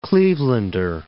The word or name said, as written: Clevelander